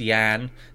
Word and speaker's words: Jan: Sorry, it's, no, it's pronounced Yan. They actually they make that clear. They put Yan underneath it, so the J is a, a, a hard J, soft J.